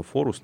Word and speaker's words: Forus: Форус, мы произносим его Форус, но внутри там зашифровано For Us, или с английского для нас.